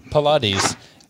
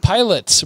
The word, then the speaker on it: Pilates: Pilates. Get out of here with that pronunciation, okay? If you're gonna want me to pronounce it Pilates, you don't get to spell it like that.